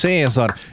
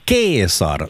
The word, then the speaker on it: Caesar: Julius Caesar, eller Julius Caesar som det ska uttalas egentligen. Jag har studerat latin, så det här är det enda jag minns från mina latinstudier. – Så vi borde äta kesarsallad. – Vi borde äta kesarsallad. C ska uttalas K.